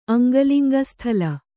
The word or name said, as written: ಅಂಗಲಿಂಗಸ್ಥಲ